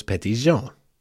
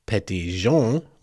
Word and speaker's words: Petit Jean: P-E-T-I-T, J-O-H-N, Petit John, John. Okay, that lends itself to a certain idea, doesn't it? Clearly how it was pronounced in the 1820s, was Petit Jean, the J, the hard, yeah. So, we know how the pronunciation should have been. Now, it also could be Petit Jean, which is J-A-U-N-E, and that means little yellow.